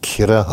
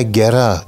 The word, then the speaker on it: Kerra: Öldükten sonra Konya'ya geldiğinde Konyalı İzzettin Ali'nin kızı Kerra Hatun'la veya Kira Hatun'la veya Gera diye de okunuyor.